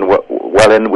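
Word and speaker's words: Welwyn: And yeah, I'm here in Welluan. The second W is silent.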